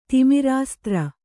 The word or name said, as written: ತಿಮಿರಾಸ್ತ್ರ